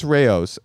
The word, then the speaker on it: Rao's: Rouse? I think you know. – Mazzetti? – Well, I think it's Rao's.